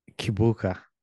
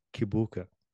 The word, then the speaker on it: Kibuka: So people in America pronounce it Kibuka. But when I'm in Uganda, they say Chibuka. It's like a C-I or C-H-I or something like that.